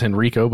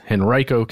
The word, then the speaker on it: Henrico: And that's how you say it, by the way. I thought it was Henrico, but it's Henrico County.